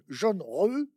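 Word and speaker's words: Zheng He: Alors, c'est écrit en français Zeng et, moi, vous dites qu'il faut prononcer jaune, jaune re.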